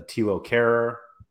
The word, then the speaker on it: Thilo Kehrer: Thilo Kehrer, That's how you say his name.